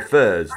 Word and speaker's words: ferz: furs. Have you heard of the furs? – The what? – Furs. It's spelled F-E-R-Z.